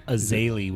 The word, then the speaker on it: Zélie: And what's the right pronunciation? – Zélie would be the French.